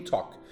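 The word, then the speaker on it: talk: تاک رو چجوری نویسیم تالک باید نوشته بشه ولی ما الش رو تلفظ نمی کنیم میگیم تاک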